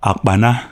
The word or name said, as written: Akpana